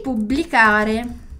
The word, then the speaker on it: pubblicare: Porque é muito sutil a diferença de um B para dois Bs, ainda mais com nessa pronúncia do blá aqui do BL, né? Então publicare, blicare, publicare. Dillo chiaro? Pubblicare.